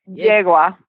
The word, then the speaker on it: yegua: I can't believe yegua is mare in Spanish. – What is it again? What's mare again? – Yegua. – Yegua? – Yegua. – Jegua.